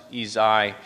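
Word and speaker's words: Ysaÿe: A composer by the name of Eugene Ysaye, it's spelled Y-S-A-E, but it's pronounced Ysaye.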